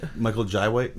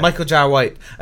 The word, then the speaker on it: Michael Jai White: They're talking about redoing it, but I was just listening to the Jean-Claude Van Damme podcast, and they were going on about, I can't even say his name, Michael Jai White. – Michael Jai White, yeah. – Michael Jai White.